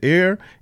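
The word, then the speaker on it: Ar: It says, Air in Moab. Air is it's a city. A-R is how it's spelled.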